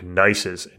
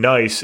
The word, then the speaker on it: gneiss: So at the roots of the Tetons are what's called these metamorphic gneisses. And gneiss is spelled G-N-E-I-S-S.